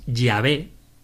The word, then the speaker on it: Yahvé: Pero hoy de lo que vamos a hablar es de con qué nombre se revela Así que, aunque os adelanto que el nombre con el que hay que pronunciar las letras de Dios es Yahvé,